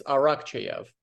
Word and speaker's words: Arakcheyev: It's Arakcheyev.